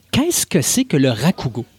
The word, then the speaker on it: Rakugo: J'ai-tu bien prononcé, Martin? – Oui, le Rakugo. – Rakugo.